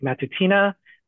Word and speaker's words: Matutina: My name is Patrick Matutina.